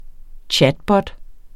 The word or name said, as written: chatbot